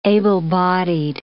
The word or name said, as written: able-bodied